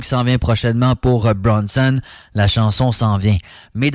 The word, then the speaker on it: BRXNSXN: On allait en faire un tour un peu dans l'ouest de la province, dans le coin de Gatineau, avec une collaboration de Bronson. Ça s'écrit B-R-X-N-S-X-N. On remplace Léo par des X, finalement. Collaboration avec un gars qui s'appelle Straz, S-T-R-A-Z-Z.